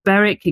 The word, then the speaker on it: Berwick: Berwick, again, we don't pronounce the W.